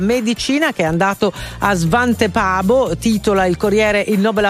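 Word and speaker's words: Svante Pääbo: Lui sarebbe svedese, quindi sarebbe Svante Paabo.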